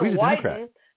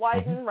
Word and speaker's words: Wyden: Senator Ron Wyden, if I'm saying that correct, I think it's hysterical because it rhymes with Biden. Ron Wyden.